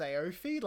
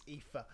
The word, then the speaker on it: Aoife: They went like, it's pronounced Aoife. And I went, where the fuck is the E there? all right, just you show me that E and I'll accept it's IFA. And he was like, no, it's Irish.